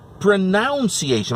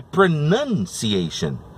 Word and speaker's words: pronunciation: Então as pessoas acabam pronunciando a, a, a palavra pronúncia como pronunciation, mas está errado. É pronunciation.